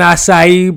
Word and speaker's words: acai: This is an acai bowl. It's pronounced like this. I sometimes have a LaCroix with it.